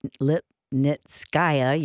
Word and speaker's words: Lipnitskaya: You recently wrote, by the way, Julia Lipnitskaya, you're right, I was mispronouncing it.